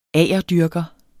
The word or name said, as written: agerdyrker